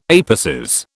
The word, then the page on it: apices: British English